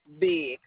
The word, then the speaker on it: Bigg: And it's spelled L-I-S-A? – Yes. – Can I get your last name? – Big. – B-I-G? – B-I-G-G.